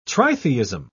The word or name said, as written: tritheism